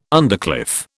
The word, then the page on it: undercliff: British English